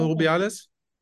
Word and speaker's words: Rubiales: Hermoso äh, geschnappt und Herr, Herr Robilias oder Robias? – Rubial, oder Rubiales.